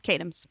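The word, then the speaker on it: Katims: Kadams. – Yeah. – You got Kadams.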